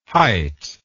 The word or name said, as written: hyte